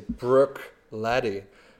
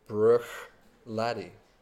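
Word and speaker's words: Bruichladdich: It's actually Buchladnich. – So both are good. The most important thing is that you don't pronounce the last two letters, the C and the H.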